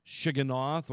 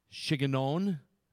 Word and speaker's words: Shigionoth: Shigionoth, or sometimes pronounced Shigionon.